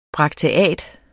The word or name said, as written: brakteat